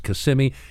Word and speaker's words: Kissimmee: It's pronounced Kissimmee."